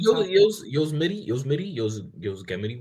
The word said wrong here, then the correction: Yosemite: whatever you pronounce it. With Gunna and Nav. – Yosemite? – Yosemite. That's a better word to pronounce it.